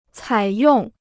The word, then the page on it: 采用: cǎi yòng